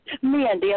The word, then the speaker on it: Mandy: Hi, this is Mandy. Thank you for having me. – Say your name again. – Mandy, I'm sorry. I'm coming down with a cold, so I apologize. Mandy, M-A-N-D-Y? – It's Mandy.